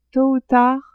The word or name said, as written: tot ou tard